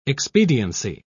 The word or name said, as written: expediency